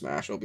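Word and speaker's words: Mashle: After it definitely should be Mashal, pronounced Mashal, because of the muscle magic.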